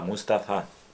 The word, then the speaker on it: Mustafa: Sin acentuación en ninguna sílaba. Mustafa, Mustafa, Mustafa, Mustafa.